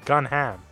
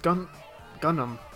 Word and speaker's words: Gundham: His name is Gunham. Or Gunham. – It's pronounced Gundam. Gundam. Like G-U-N-D. H-A-M.